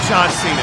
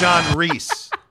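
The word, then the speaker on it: John Rhys: It's not John Cena, it's John Reese.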